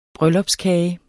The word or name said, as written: bryllupskage